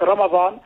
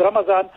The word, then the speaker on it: Ramadan: But Ramadan, there are different pronunciation of the word. So you will find that the actual word would be what Ramadan, but in certain, like for example, the Indo-Pak subcontinent, they will use the word Ramadan. So it is it is both the same thing, but in terms of different places of the world pronouncing it differently.